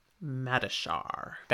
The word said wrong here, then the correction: Mashadar: That's not how it's pronounced. – I said it wrong. I mean, look at the spelling. – Yep. – Mashadar. That's what I meant.